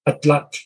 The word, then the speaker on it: atlatl: Where you kind of, the last TL, you instead of, you can say like a T, but if you do the same thing, but use the sides of your tongue, that's how you pronounce that last TL. Atlat.